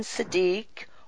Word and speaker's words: Siddique: We are delighted to welcome John Siddique,